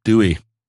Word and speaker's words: Douwe: And oh, it's not spelled like I thought it would be. D-O-U-W-E. – Yeah, Dewey. Dewey Egberts.